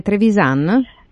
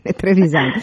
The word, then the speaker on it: Trevisan: Ecco, io dico Trevisan come dicono i milanesi, ma è Trevisan? – È giusto, ecco, Trevisan. Ecco, perché sì. ho sentito l'accento veneto e quindi adesso mi correggo immediatamente, quindi Trevisan.